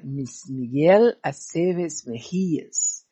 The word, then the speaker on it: Miguel Aceves Mejía: O sobrenome não sei escrever certo, mas é assim que se pronuncia. É Miguel Aceves Mejías.